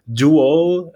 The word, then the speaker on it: Dual: Euh, j'ai l'impression que ça n'a pas suivi nécessairement en français parce que Dual, euh, version 2022, s'écrit D-U-A-L, comme une espèce de dédoublement ou un double, mais se prononce comme le mot duel, donc affrontement à mort généralement, entre deux personnes.